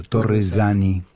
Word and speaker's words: Torresani: Y luego hablaremos con la licenciada María Elena Torresani, pronunciado en italiano, Torresani, una sola S, Torresani,